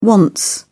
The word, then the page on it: wants: British English